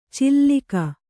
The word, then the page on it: ಚಿಲ್ಲಿಕ: cillika